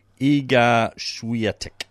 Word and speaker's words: Iga Świątek: From Poland, it's Iga Świątek.